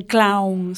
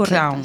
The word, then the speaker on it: clown: que como debo pronunciar isto para seguir sendo unha capitana afamada. – Ben, clown sería a pronunciación. – Correcto, clown.